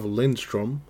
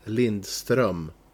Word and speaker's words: Lindström: Then we have Gustav Lindström, as you call him. He's actually Gustav Lindström.